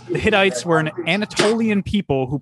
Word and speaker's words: Hittites: I think it's called Hittites. I don't think it's called Hitties. – Hitties, man. Big old Hitties. It's big Hitties. – Old, yeah. I think it's pronounced Hittites. – I think it's Hitties, man. – It's definitely not Hitties. – H-I-T-T-I-T-E-S is Hitties.